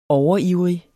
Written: overivrig

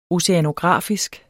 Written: oceanografisk

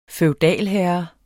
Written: feudalherre

